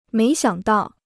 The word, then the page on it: 没想到: méi xiǎng dào